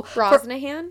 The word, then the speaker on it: Brosnahan: Rachel Broshanahan. I'm gonna pronounce that wrong. That's cool. – Brosnahan.